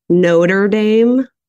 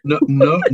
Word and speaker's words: Notre Dame: Although I think you should say it Notre Dame, not Notre. Say Notre. Yeah. See how painful that is. – Notre Dame.